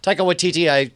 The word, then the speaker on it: Waititi: I was talking about how the director, Taika Waititi, am I saying that right? – Taika Waititi.